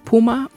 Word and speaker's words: phuma: The first is the word Ungazawele, which is spelled U-N-G-I-Z-W-I-L-E, which means you heard me. The next is the word Puma, which is spelled P-H-U-M-A, and it means get out.